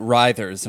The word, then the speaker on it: Writhers: But then I didn't want to be wrong, so I searched around on YouTube and I found an interview with you and another band member, and he pronounced it Writhers, and I was like, "Ooh, I'm glad I watched that."